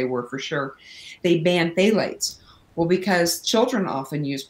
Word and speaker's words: phthalates: It's spelled with a PH, but it's pronounced phthalates.